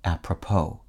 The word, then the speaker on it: apropos: From the French phrase apropos, meaning to the purpose, the word's emphasis lands on its last syllable, which ends in a silent S, apropos.